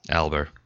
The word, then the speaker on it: Alber: Alber. A-L-B-E-R. – I always want to put a T on there. It's no T. It's not Fat Albert. It's Matt Alber. – No T, no Albert.